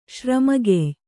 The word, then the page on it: ಶ್ರಮಗೆಯ್: Kannada